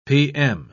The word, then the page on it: p.m.: British English